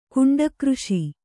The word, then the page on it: ಕುಂಡಕೃಷಿ: Kannada